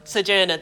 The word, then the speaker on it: Sojourner: Sojourner, there we are, thank you.